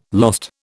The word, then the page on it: lost: British English